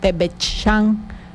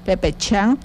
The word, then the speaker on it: pepechán: Entonces lo que cambiaría nada más es el té, ya no sería árbol de guano, sino nada más la hoja de guano, que en yocotán se menciona pepechán, que se escribe pepechán.